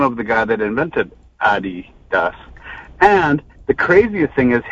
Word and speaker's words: Adidas: But it's actually pronounced Adidas. – Adidas? – Adidas. – Adidas? – Adidas is actually Adidas.